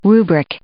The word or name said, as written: rubric